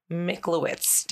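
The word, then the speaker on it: Miklowitz: She has earned her doctorate in clinical psychology at CU Boulder, and she completed her pre-doctoral clinical training at UCLA with Dr. Michlowitz.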